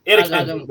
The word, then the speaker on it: Anna Kendrick: Anna Kendrick.